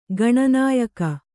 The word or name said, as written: ಗಾಣನಾಯಕಿ